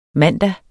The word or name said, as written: mandag